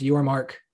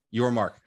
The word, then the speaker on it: Yormark: And I think Brett Yormack is the kind of guy that's going to get creative. First off, hold on. It's like, you got mad about mispronouncing. It's Yormark. – Yormark. Got it.